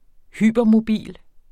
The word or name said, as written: hypermobil